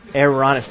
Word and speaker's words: Aaronic: So only, this is the Aaronic, not speaking of air, Aaronic. It's kind of hard to pronounce that. A-A-R-O-N-I-C, the Aaronic priesthood.